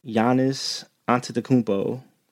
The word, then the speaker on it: Giannis Antetokounmpo: Speaking of really great dunkers, Giannis Antetokounmpo.